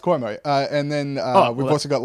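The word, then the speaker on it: Cuomo: I have told you how to pronounce it at least six times, so give us your best get bear on how it is pronounced. – Kuomo. – Yeah, Kuomo, you got it, brothers.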